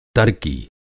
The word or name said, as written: तरकी